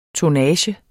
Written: tonnage

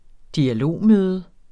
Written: dialogmøde

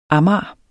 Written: amager

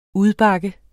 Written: udbakke

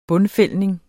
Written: bundfældning

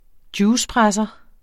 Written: juicepresser

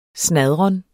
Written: snadren

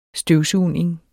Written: støvsugning

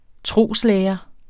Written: troslære

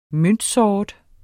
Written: møntsort

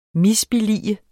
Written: MISBILLIGEDE

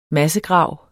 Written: massegrav

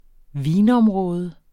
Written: vinområde

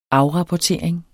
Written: afrapportering

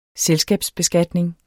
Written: selskabsbeskatning

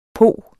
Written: pog

